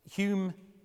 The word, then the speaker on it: Hume: Notice, by the way, that Hume, for the Scots, is spelt H-O-M-E. Hume changed his name to H-U-M-E when he came down to Bristol in 1734. He was fed up with the English mispronouncing his name.